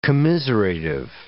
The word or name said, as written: commiserative